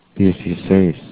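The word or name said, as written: dieciséis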